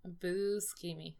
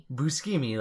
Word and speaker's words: Buscemi: Buscemi. – Buscemi, like B-U-S-C-E-M-I, which the dude's name is just Buscemi. – Yeah. – There's no, like, funky pronunciation on there.